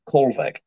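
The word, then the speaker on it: Kohlweg: So you'll find it at M Kolvegg.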